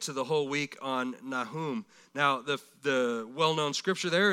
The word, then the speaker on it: Nahum: Now, the next one, excuse me, prophet number seven is Nahum, or Nahum, pronounced Nahum, ministered in Nineveh about 650 or so BC.